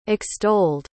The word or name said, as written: extolled